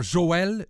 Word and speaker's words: Joël: A trema no E, em francês, implica a pronúncia do E. Por exemplo, Citroën, ou melhor, Joël. Joël tem uma trema, não é? E essa trema implica dizer o E.